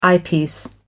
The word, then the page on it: eyepiece: American English